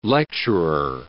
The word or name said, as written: lecturer